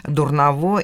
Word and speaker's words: Дурново: Но чтобы э, их а, ограничивать или отличать от соответствующих слов, да, они имели ударение на последний слог. Вот такого типа плохово, недоброво, дурново и так далее.